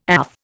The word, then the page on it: AF: Indian